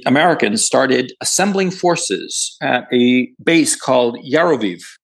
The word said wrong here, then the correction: Yavoriv: Yavoriv. I always mix up the pronunciation. Yavoriv.